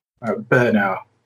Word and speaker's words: Brno: Brno.